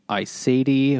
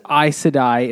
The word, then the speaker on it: Aes Sedai: So it is Aes Sedai, and I really just pronounced it that way because that's the way I didn't even realize this.